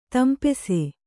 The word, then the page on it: ತಂಪಿಸು: tampisu